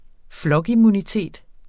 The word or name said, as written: flokimmunitet